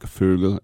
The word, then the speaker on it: Vogel: Sam Cassell, believe it or not, his name was mentioned, and Frank Vogel, or you pronounce F's like, sorry, V's like F's in German, Frank Vogel, that means bird.